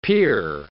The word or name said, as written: peer